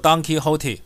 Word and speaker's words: Don Quixote: 西班牙小说家塞凡提斯作品《堂吉诃德》的直接发音叫做 Don Quixote，我在猜是不是就是因为 Donkey 跟驴子的发音 Donkey 是一模一样的，因此呢，他事实上开这家店的原因就是把这些来店的客人通通当成像驴子 Donkey 这样一样傻的动物来看待吧。